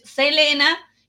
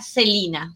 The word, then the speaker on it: Selena: Y yo canté PRI a mi artista favorita asesinada por fanáticos, que para los latinos es Selena, pero en realidad su nombre pronunciado, como ella misma lo pronunciaría, era Celina, Celina Quintanilla.